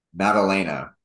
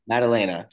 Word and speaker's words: Maddalena: So Jack Melandana, whatever the hell his name is, how do you say it? Maddalena. Maddalena. Jack Della Maddalena.